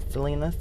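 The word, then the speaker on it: Salina: All of, you know, Twitter, what's the other, Instagram, and all of the social media platforms, it's the same name, Selena Samone. It's spelled S-A-L-I-N-A-S-A-M-O-N-E.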